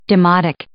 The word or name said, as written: demotic